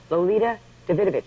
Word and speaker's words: Lolita Davidovich: I mispronounced her name. It was Lolita Davidovich.